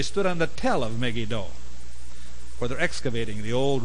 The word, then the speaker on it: Megiddo: But the one event that I remember very, very strongly took place at Megiddo, the Megiddo of Armageddon, but they pronounce it Megiddo. You must never say Megiddo. It's Megiddo.